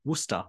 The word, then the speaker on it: Worcester: All of the characters, including Coach Lingle, constantly pronounce Worcester College's name incorrectly, as if it rhymes with rooster, so like Rooster College, whereas the correct Worcester. Pronunciation is Worcester, like, you know, the Massachusetts city.